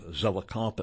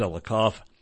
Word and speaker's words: Zelikow: And as we're going to see, Philip Zelikoff, I believe that is how his name is pronounced, his last name is spelled Z-E-L-I-K-O-W. I believe that is pronounced Zelikoff, and perhaps Zelikow. I think it's Zelikoff.